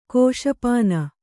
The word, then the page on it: ಕ್ಷಪಣ: kannada